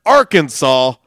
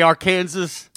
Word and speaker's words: Arkansas: Did you know, that it is illegal to mispronounce the word Arkansas in Arkansas. – Not, you can't say Arkansas.